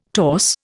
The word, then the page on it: torse: Indian